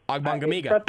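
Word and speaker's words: Ogbongbemiga: Ogbonigbeniga. I got it. – There are people in Oklahoma who still can't pronounce his last name well. I don't know why I can't, but... – You're nailing it, and you're flexing for the camera.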